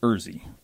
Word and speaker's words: Urzy: We've created a new product called Urzy. – Urzy. How do you spell that? – U-R-Z-Y.